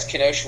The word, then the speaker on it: Kenosha: Well, we are going into a serious conversation today because we are going to be talking about Kenosha. I believe I'm saying that correctly. – Yeah. Kenosha, Wisconsin. I'm not trying to be funny here,